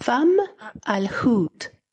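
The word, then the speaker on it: Fomalhaut: Y me mandó un audio con la pronunciación. Atención. A ver, a ver. – Fam al-Hut.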